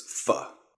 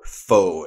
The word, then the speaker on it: pho: Well, another of my favorites on the road is pho, or pho as us white people tend to say, or the Americans say. Pho. P-H-O.